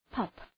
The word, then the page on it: pup: pʌp